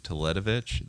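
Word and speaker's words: Teletović: And I think Teletovic, that's how you say his name.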